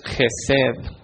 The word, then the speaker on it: Hesed: La palabra es Gesed, se escribe H-E-S-E-D.